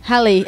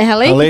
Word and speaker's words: Raleigh: É, Você tá falando de Raleigh? – Raleigh. É Raleigh, que fala? É Raleigh.